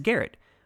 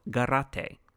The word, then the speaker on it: Garate: Without any sort of guide to go off of, I pronounced his last name as Garrett, which is how it looks if it were an English name. But a friend and former co-worker reached out to me to let me know that she'd actually met him many years ago, and the last name was pronounced as it would be in Spanish, so Garate.